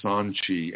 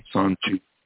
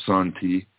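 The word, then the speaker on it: sanqi: And it's called, the pinyin for it is Sanchi, S-A-N, either T-I or Q-I. It's spelled both ways in pinyin. – Okay. – Sanchi or Santi.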